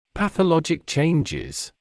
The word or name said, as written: pathologic changes